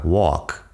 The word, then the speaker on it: walk: Dos ejemplos muy comunes son la L en walk y la T en listen. Si te fijas, walk suena como, como si fuera la comida asiática, walk.